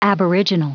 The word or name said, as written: aboriginal